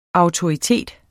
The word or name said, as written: autoritet